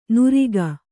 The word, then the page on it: ನುರಿಗ: nuriga